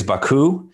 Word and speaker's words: Baku: The city is Baku, and the country is Azerbaijan.